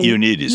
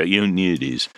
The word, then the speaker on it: Ioannidis: Ioannidis. – Yes. – Yeah, Ioannidis.